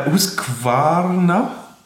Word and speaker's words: Husqvarna: Ja, Husqvarna. Habe ich es hoffentlich richtig ausgesprochen? – Ja, ich glaube, mittlerweile kannst du es besser als ich.